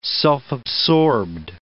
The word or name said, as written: self-absorbed